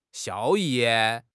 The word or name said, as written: 效益